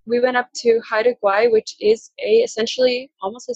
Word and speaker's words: Haida Gwaii: And then you were also, we almost met up in person because you were in Haida Gwaii. That's how you say it, right? – Yeah, Haida Gwaii.